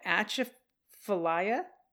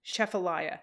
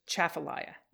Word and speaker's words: Atchafalaya: My very southern grandmother won't stop rolling in her metaphorical grave until I tell you that atchafalaya, despite its spelling, is pronounced chafalaya. – Wow. – So atchafalaya is actually just chafalaya. – Okay. – With the double accent on the chaff and the lie.